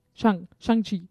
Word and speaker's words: Shang-Chi: So in cinemas, Shang-Chi, yeah. – I think that's how you pronounce his name. Shang-Chi.